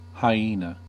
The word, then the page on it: hyena: American English